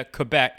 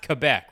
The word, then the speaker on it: Quebec: It's pronounced Quebec, right? Not Quebec. – Yep. – Okay. – Yeah, good. Quebec. – I didn't want to be a bad American Quebec and be like that, you know? – It depends. I don't really know what's the – I mean, we pronounce it different in French. So Quebec, yeah. Quebec's fine.